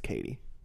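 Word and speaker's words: Cady: Also, Katie is spelt like Caddy. – Caddy Heron. – from Mean Girls? – From Mean Girls, yeah. – Yep. – It's frustrating. – It's just pronounced Katie.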